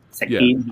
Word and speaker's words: Shek Kin: Uh, the end fight with him and uh, was it Shek Kin? Did I say it right? Shekin. Help me out. Shekin, yeah. yeah.